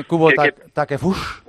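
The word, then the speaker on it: Kubo Takefusa: ¿Se dice bien Kubo Takefush? – Sí, tú pronúncialo exactamente igual que lo leas. Es lo único bueno que tiene el japonés.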